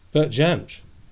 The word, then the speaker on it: Bert Jansch: Do you know that Bert Jan? Is it he? How do you need to say his name? Bert Jans or Jan Jans? – Bert Jansch. – Yeah, Jansch. Yeah, of course, that's it.